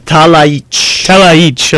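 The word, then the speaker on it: Talajić: Talaich, okay. – Yes, the J's are soft.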